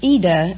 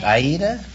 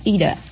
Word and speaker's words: Ida: Well, it's Ida in Italian, but Oh, like Aida? – Well, no, there is no A. It's just Ida, I-D-A. – Oh. – But the American pronounce it Ida.